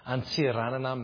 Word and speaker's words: Antsiranana: In a place called Diego Suarez, or in Malagasy, it's pronounced Antsiranana, Madagascar.